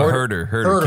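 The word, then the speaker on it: Huerter: Am I pronouncing his name correctly? – No, Hoyter. Kevin Hoyter.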